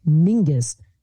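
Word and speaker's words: Menzies: Yeah, which to say to Menziesii, which my friend Ross said, oh, it's actually, there's no letter for the sound, but it would be pronounced Mingus.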